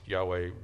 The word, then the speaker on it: Yahweh: If you know that it's pronounced Yahweh, Jehovah is a rough translation of Yahweh.